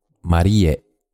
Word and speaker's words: Marie: Kondo's first name, Marie, is actually pronounced Marie, but to the English speakers, it just looks like the Western name Marie and is easy to pronounce.